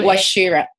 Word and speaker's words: Wachira: And your last name, it's Naomi Wachira. Yeah, Wachira.